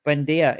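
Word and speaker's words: Buendía: Buendia.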